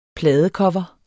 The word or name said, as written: pladecover